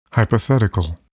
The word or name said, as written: hypothetical